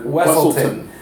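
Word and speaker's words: Weselton: Duke Weaselton. And in Frozen, he was Duke Wesselton. And they mispronounce it in both. And so he corrects them in both.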